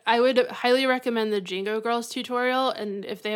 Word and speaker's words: Django: How do I say it? – The D is silent. – Oh, okay. Django,